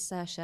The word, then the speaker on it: Saoirse: Boyd, I really wanted to be the one to say that the way you pronounce it is Saoirse, and you've taken that away from me now.